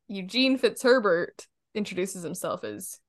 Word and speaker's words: Eugene: Just hate how she says Eugene. – The emphasis on the wrong syllable.